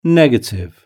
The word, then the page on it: negative: British English